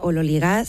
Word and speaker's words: ololigas: Teóricamente se pronuncia ololigas según la... La transcripción fonética del griego al español,